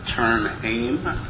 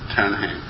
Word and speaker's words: Ternheim: Am I pronouncing your name right? – Yeah, I think it sounds pretty good. – Because I've heard some Swedish radio broadcasts where they pronounce it Ternheim. – Well, if I would pronounce it in Swedish, it would be Ternheim.